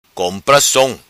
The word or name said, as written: Som Compra